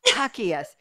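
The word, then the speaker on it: Jacquais: We have Deslin Hakias. Did I say it right, Deslin? – Yes, you did. Great job.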